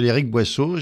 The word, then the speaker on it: Eric Boisseaux: Bien, écoutez, moi, je m'appelle Eric Boisseaux.